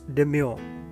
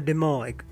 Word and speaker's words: demur: It's D-E-L-U-G-E. It is spelled as D-E-L-U-G-E. It means a great flood or a heavy downpour. The next word is demure or demur. Actually, it's demur. It's D-E-M-U-R. To voice opposition, object. The another word is similar to demur. It is demure. It's D-E-M-U-R-E.